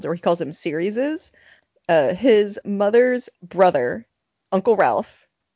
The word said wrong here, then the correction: Ralph: Rafe, Rafe, sorry. Rafe. It's spelled Ralph, but yeah. – They're British.